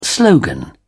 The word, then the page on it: slogan: British English